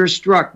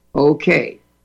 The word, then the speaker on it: Strzok: Strzok. So I guess the Z is silent.